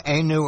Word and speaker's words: Ainu: No Ainu or Polynesian in the genome. No European in the genome at this date. Did I say that correctly, Professor? – You did.